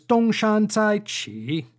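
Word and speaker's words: Dong Shan Zai Qi (东山再起): Our Chinese saying for this episode is Dong Shan Zai Qi, and as we always seem to do, and for good reason, I suppose, let's break these four syllables down.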